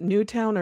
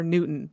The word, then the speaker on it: Newtown: Yeah, I'm like, I can't remember if they pronounce it Newtown or Newton. That was just me. – It just depends on who you talk to.